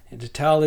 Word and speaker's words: Neanderthal: Now, when you think of other species of hominids, the one that comes to mind is, of course, Neanderthal or Neanderthal. Neanderthal is the commonly accepted pronunciation in the modern era.